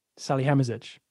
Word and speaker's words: Salihamidzic: Salihamidzic.